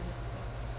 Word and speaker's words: mitzvot: These words at the conclusion of verse 40 could be taken as a relational reason for keeping the mitzvot.